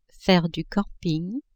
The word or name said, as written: faire du camping